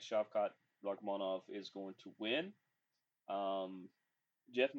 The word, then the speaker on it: Rakhmonov: I heard him say his name the other day, and that's why I'm calling and why I'm saying it that way. It's Rakhmonov. Shavkat Rakhmonov.